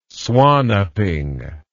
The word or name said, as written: swan upping